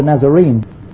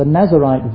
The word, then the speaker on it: Nazirite: The person who lived in Nazareth was a Nazarene. This is Nazarite, not Nazarene, and it's not even spelt the same way. It's spelt N-A-Z-I-R-I-T-E, not N-A-Z-A-R.